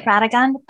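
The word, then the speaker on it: Radegund: Ragund, am I saying that right? – I say it Radagund, but, you know, Ragund. You may be saying it correctly, because I think with a lot of these pronunciations, no one really knows.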